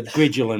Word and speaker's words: Griegel: Griegel and Bain from 2005. – I think it's a soft G, so Griegel and Bain, but anyway.